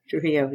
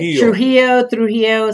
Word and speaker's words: Trujillo: Trujillo. Yeah, Joanne. – Say it the other way, too. – Trujillo, Spanish, Trujillo, I don't care.